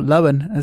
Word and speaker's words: Lohan: Is because me and Joel are so young, I know about Fully Loaded, and that is the remake with Lindsay Lohan. – Mm-hmm, mm-hmm, mm-hmm. – Or Lohan, as it actually is pronounced.